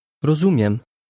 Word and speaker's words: Rozumiem: Rozumiem. Miem. Miem. Zumiem. Zumiem. Ro. Rozumiem. – Did you hear the M sound at the end?